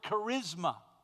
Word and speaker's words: charisma: Here's how you say it in English. It's the word charisma. Charisma.